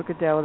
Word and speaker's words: Godot: It's also Waiting for Godot. That's a French pronunciation.